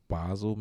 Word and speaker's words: Basel: But no, Art Basel is and I don't even think I'm saying it right. I think it's Basel, maybe. But Basel is pronounced so many different ways. – Yeah, it's actually a city in Switzerland where this event started, and it's an art festival.